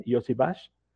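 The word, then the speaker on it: Iosivas: Tiene un nombre un poquito extraño, que es Andrei Yoshibash. Así se pronuncia. Yoshibash.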